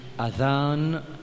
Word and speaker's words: أذان: Sa vraie prononciation, c'est Adhan avec Zal qui nous prononce dans bout de la langue. Mais en créole, nous y dit Adhan.